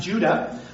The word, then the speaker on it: Judah: In the Hebrew, it would have been pronounced Judah, like the patriarch.